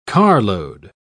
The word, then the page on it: carload: British English